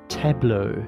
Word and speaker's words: Tableau: Put through Tableau. That's how you pronounce it.